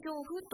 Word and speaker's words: 上布: 上布とは上下の上に布と書きます。これで上布と読むんですね。